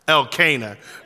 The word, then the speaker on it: Elkanah: Elkanah.